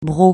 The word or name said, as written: broc